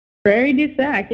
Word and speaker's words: Prairie du Sac: Prairie du Sac? – Prairie du Sac, yeah.